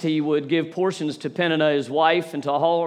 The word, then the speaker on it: Peninnah: Just to let you know, I actually looked up how to pronounce Peninnah. That's right. It's Peninnah.